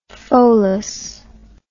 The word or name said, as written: pholas